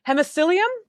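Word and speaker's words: Hemiscyllium: So you have another article called Walking, Swimming, and Hitching a Ride, the Phylogenetics and the Biogeography of the Walking Shark Genus, Hemiscilium? Did I say that right? – Yeah, that's exactly right.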